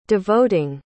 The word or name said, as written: devoting